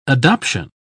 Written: adoption

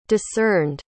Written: discerned

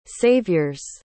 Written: savior's

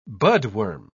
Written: budworm

